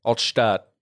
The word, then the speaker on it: Altstadt: Altstadt.